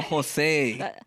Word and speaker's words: Josei: Dude, are we going to get more Josie? – Josie? – Wait, is that how you pronounce it? Fuck, I'm butchering it. – Wait, what? – J-O-S-E-I? – Jose.